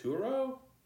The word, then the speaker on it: Turo: It's called, I may not be pronouncing it, Turo? – Yeah.